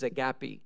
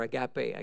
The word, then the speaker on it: agape: but one of them is agape, or agape, I guess you pronounce it.